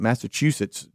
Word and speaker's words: Massachusetts: We have a 19-year-old Massachusetts driver.